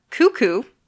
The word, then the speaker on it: Cuckoo: Well, we've also got Cuckoo, even though it looks like it's spelled Cuck-Coo. That's not how it's said, just so you know.